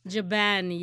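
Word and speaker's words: Jben: Is it Jaben? – Jaben, yes, yes, yes.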